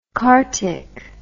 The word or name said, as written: kartik